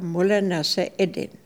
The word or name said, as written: Nassr Eddin Mullah